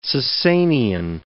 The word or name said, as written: Sasanian